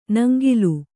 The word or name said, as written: ನಂಗಿಲು